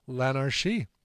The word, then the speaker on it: L'Anarchie: You don't pronounce it as a hard CH, I'm finding out. Okay. L'Anarchie.